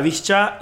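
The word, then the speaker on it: Višća: Marek Hamšík, la leyenda del Nápoles, clave en el título de liga de la temporada pasada, y también Edin Visca. No, o sea, se lee como Visca al Barça, pero se pronuncia con una CH.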